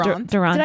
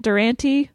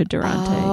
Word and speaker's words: Durante: Well, I grew up calling it Durante. – Really? – Like, that's how my family pronounces it still. And then I graduated college. I was like, I do not like this pronunciation. So I changed it to Durante.